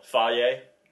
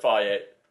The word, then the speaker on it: Fage: It's called Faye, spelled phage, F-A-G-E. But they pronounce it Faye.